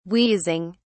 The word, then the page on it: wheezing: British English